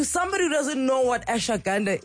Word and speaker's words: ashwagandha: And finally, we're going to have this conversation about ashwagandha. Am I saying it right? – Ashwagandha, yes.